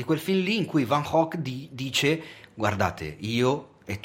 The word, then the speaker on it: Van Gogh: si dovrebbe pronunciare così Van Gogh.